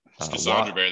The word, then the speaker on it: schisandra berry: I'm not even sure how to pronounce schisandra berry. – Yep. Very good. Yep. Schisandra berry. That's how I pronounce it.